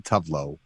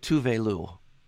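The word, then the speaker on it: Tove Lo: It's Tovelo, and it's not Tovlo. I know it's different. It's called Tovlo. – She pronounces it Tuvelu.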